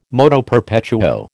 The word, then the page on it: moto perpetuo: American English